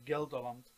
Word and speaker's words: Gelderland: which is called I hope I pronounce it correctly, Gelderland. One of your EPs was even called Gelderse Poort. With my little knowledge of Dutch, I guess that this is either the portal to Gelderland or the harbor. – Yeah, it's the gate. It's basically what it means. Yeah, yeah. And you're pronouncing it pretty well.